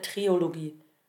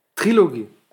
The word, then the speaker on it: Trilogie: Kann man Trilogie sagen oder ist es Trilogie? Da kommt auf die Aussprache an, ne? Was kann man sagen? Trilogie oder Triologie? – Trilogie.